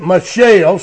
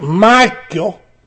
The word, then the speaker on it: Michal: The middle of verse 11 says, and Michal, I heard one fellow pronounce it Michelle so they'd know it was a girl. I think it should be pronounced Michal.